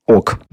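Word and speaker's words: oc: слово «да» произносилось как «ок».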